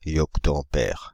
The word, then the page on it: yoctoampère: [jɔk.to.ɑ̃.pɛʁ]; /jɔk.to.ɑ̃.pɛʁ/